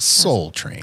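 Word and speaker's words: Sol Train: S-O-L Train. – Ah. Yeah. – Soul for Set List of Life, that's pronounced Soul Train.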